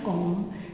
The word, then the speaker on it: Om: La sillaba Om, che di fatto è fatta di tre elementi, A, U, M, ma si pronuncia Om, che viene cantata moltissimo con un tono prolungato, è interessantissimo sentirla.